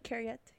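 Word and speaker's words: cariad: Carriette. Is that how you pronounce it? Carriette, yeah. Mm. – Means darling, or like dear. It's a, a term of endearment in Welsh.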